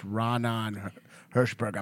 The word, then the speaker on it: Raanan Hershberg: He was nice, but he was like, all right, I want to make sure I got your name right. So it's Raanan Hershberg. I'm like, yeah, that's it.